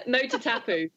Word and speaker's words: Motatapu: So you threw yourself into an off-road adventure, and you'll have to correct me or tell me how to say this. So Matatapu? – Motatapu. – I was kind of close. – Motatapu. So, Motatapu. I like that.